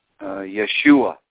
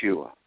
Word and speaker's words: Yeshua: But for those who think the name, the actual pronunciation of the name is important, it's probably Yeshua.